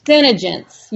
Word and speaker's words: CeneGence: So you're from, is it, how do you pronounce it? CeneGence? – CeneGence, yes.